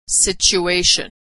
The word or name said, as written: situation